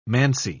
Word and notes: -mancy: British English

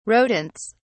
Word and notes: rodents: British English

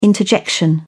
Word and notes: interjection: British English

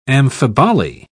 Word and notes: amphiboly: British English

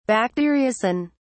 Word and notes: bacteriocin: British English

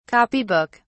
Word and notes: copy book: British English

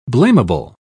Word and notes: blameable: British English

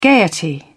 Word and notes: gaiety: British English

